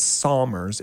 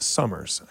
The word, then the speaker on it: Somers: And so for those of you who are new to the valley, which I know there's quite a few, though, it's not Somers, it's Summers. I know it's pronounced with a U, although spelled with an O.